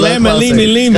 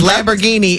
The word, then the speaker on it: Lamborghini: Get closer than lemon, limi, limi. – Because Lamborghini is 4 syllables. Lamma, lima, lima. You gave 6, baby. Right. – One is a noodle and one is a car.